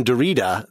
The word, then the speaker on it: Derrida: Well, the fact that you called him Derrida, then yes, that's good.